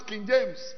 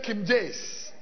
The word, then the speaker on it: King James: And some of you cannot even pronounce King James. You say Kim Jace. Kim Jace.